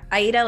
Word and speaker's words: Aida: Yeah, Aida, like the opera. – Gotcha. I love it. – I always go by Kelsey Aida, because Roualdes is like a whole long, lots of vowels, confusing how to say it.